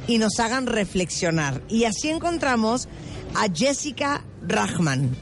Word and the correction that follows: Reichmann: ¿Lo dije bien? Reichmann. – Reichmann. – Jessica Reichmann. O sea, eso es que es alemán.